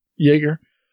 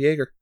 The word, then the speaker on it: Jaeger: You get the Gypsy Avenger, Geiger, not too sure. – Jaeger. – Yeah, I haven't seen the movie, so I don't know how they pronounce it. And Shrike Thorn. – Same way as they do in Heavy Gear. It's a Jaeger.